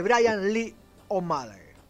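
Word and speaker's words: Bryan Lee O'Malley: la de Brian Lee O'Malley. No sé si lo dije bien, niño de cobre, que también es profe de inglés. Sí, me dijo que está bien.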